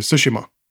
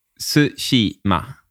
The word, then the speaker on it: Tsushima: Att, eh, jag har lärt mig nu att det uttalas Tsushima. – Tsushima. – Mm, mer, platt. Liksom, mer platt, ja. – Tsushima. – Ja, ingen, ingen eh, betoning på i va?